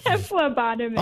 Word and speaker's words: phlebotomist: phlebotomist? Did I say that right? – Phlebotomist.